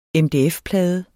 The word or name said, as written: MDF-plade